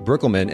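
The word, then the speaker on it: Breukelman: And I have with me the lovely and professional and experienced, actually, Megan Brookelman.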